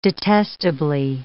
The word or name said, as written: detestably